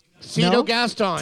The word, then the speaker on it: Cito Gaston: Am I saying this right? Cito Gaston? – Cito Gaston. – Tell me how to say it.